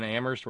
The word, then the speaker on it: Amherst: I mean, Kitty, Terry in Amherst, where only the H is silent, Charlene.